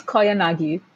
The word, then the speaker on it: Koyanagi: It's Koyanagi.